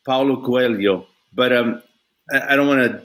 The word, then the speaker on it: Coelho: I'm hearing you pronounce the other Paulo guy, and that's the L and the H go Lio, Paulo Coelho.